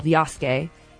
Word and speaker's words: Yasuke: I'm assuming it's called Yasuke.